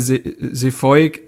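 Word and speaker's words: Zeefuik: Ähm, übrigens wird der Sefoik wie quasi Johann Kreuf ausgesprochen, aber das kann ja schon, auch das kann ja kein Deutscher richtig. Also dieses EU wird ja so ganz weird ausgesprochen. Das kriegt keiner hin.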